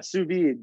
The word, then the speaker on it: sous vide: sous vide. Am I saying that right? Sous vide. Is that how I'm saying it? – Yeah, sous vide.